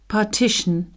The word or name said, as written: partition